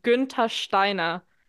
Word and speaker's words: Günther Steiner: Oh, come on, like, please, even his name, like, all of you pronounce it so English, but if you really want the real pronunciation, it's Günther Steiner.